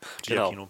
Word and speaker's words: Giacchino: So, du meintest, du hast es? Giacchino? Giacchino, glaube ich, wird ausgesprochen. Giacchino. – Giacchino. Genau.